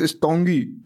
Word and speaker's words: Tanguy: It's Tongi.